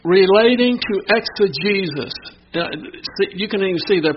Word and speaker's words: exegesis: Exegesis is spelled E-X-E-G-E-S-I-S. The last part sounds like Jesus, but it's not spelled that way. It's not talking about Jesus, but it's called exegesis.